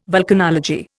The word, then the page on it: vulcanology: Indian